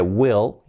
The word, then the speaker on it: will: Se va a olvidar la pronunciación de will, importante que tenemos la I, letra clave, que no sea will, no, will, casi una E, y la L, will, will, will, will, así sería. Volviendo al condicional,